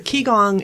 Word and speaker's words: Qigong: And Qigong. Is that how you say it? The doctors give you an assigned motion, don't they? – Well, to free something? – Yes. A Qigong, it's spelled Qigong. And you can YouTube it and you'll find tons of people who do different videos and practices.